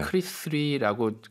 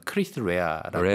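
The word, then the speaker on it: Chris Rea: 크리스 리라고 그동안 몇십 년 동안 저는 불렀는데 이게 이제 표준어 발음법으로 하면 크리스 레아라고 레아더라고요? – 하더라고요.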